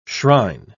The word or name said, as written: shrine